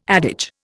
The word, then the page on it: adage: Indian